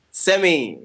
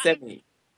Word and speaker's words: Semi: Semi. It's pronounced Semi.